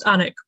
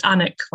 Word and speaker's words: Alnwick: So it's Anik, like the first few syllables of anarchism, which amuses me.